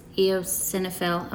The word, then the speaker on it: eosinophil: so there, um, so this eosinophil, am I saying that right? Oh, yes, good.